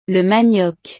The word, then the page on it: le manioc: frances